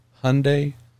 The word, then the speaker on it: Hyundai: But nonetheless, I'm told by folks whose company name is not originating from English that the correct way for us English types to pronounce it is Hyundai like Sunday.